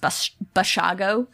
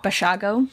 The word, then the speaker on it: Basiago: Andrew, actually, I think his name is pronounced Basiago. – Oh. – Basiago.